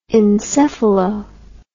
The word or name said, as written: encephalo